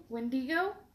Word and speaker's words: Wendigo: Wendigo? Is that how it's pronounced? – Yeah.